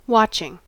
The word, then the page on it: watching: American English